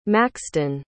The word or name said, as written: maxton